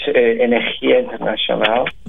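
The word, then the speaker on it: Energía Internacional: In September, you have an interesting program, which is called Energia Internacional. Did I pronounce that correctly? – Yeah, it's in a Spanish language, Energia Internacional.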